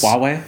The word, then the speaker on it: Huawei: And this is such an old-school like story, but basically, Gal Gadot, Wonder Woman, who's an ambassador for Huawei. Is that how you say it? Huawei? Mm-hmm. – I like the amount of H you put on that too. It's Huawei. – Yeah, that was perfect. Huawei.